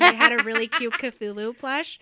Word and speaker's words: Cthulhu: This is the part where I publicly talk about the fact that I can't pronounce Cthulhu. Cthulhu. – That's right. That's right. You got it right.